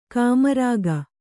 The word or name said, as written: ಕಾಮರಾಗ